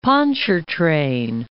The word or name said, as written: Pontchartrain, Lake